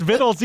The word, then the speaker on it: victuals: They're not victuals, even though it's pronounced vittles either way.